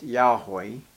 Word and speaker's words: Yahweh: Even the Lord, all capital, this is the name, the holy name of God, normally pronounced Yahweh, okay, when it's in caps.